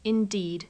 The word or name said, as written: indeed